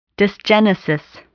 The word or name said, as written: dysgenesis